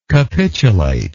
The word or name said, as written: capitulator